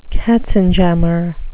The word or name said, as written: katzenjammer